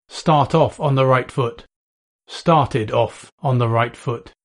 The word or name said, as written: start off on the right foot